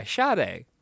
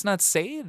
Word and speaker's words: Sade: I'm like, yeah, Sade. That's how that's pronounced. It's not Sade.